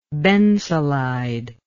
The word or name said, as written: bensulide